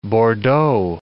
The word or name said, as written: Bordeaux